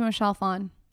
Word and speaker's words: Michelle Phan: Shout out to Michelle Phan.